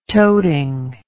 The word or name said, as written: toting